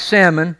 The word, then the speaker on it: Salmon: It's spelled like salmon, but don't say it like that because people will think you're weird. It's Salmon, okay?